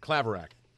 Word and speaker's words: Claverack: Now, do you say Claverick or Claverack? – Claverick. – Aha! You're right.